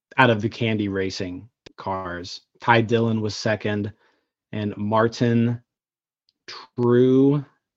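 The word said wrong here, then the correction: Truex: Jr. I'm not sure if that's how you pronounce it. Tro. – Yes. – Martin Tro Jr. It's Tro.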